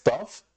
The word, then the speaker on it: Toph: Que es Tough. se escribe T-O-P-H, pero se pronuncia como top de fuerte, ¿no?